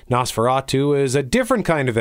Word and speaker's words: NOS4A2: If you say that phonetically, it sounds like Nosferatu, i.e. vampire.